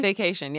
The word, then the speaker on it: Vacation: Is that, am I saying it correctly? – Vacation, yeah. Okay.